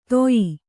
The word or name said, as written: ತೋಯಿ